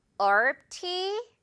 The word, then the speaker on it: herb: Would you like some herb tea? Would you like some herb tea? 이렇게 발음을 안 할 거면 왜 쓰는지 스펠링 그쵸? 조금 이해가 안 되긴 하지만 영어는 그렇다는 거.